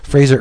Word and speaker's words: Fraser: Written by John Rosen, illustrated by Fraser Irving. Fraser rhymes with laser. Fraser Irving, it is gorgeous.